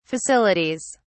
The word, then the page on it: facilities: British English